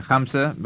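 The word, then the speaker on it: خمسة: With the numbers 3 through 10, the A sound at the end of the word drops out before whatever is being counted. So before a word like dollar, for example, thalatha becomes thalath, and khamsa becomes khams.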